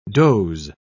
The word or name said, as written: doze